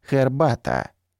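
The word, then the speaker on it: herbata: И еще один пример, совершенно выбивающийся из нашей системы, это польское слово «хербата».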